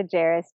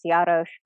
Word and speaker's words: Jaros: So if people want to find me on Instagram, it's just Amanda Jaros, J A R O S. Um, it is a Czech last name. It's pronounced Jarosz, but we've Americanized it.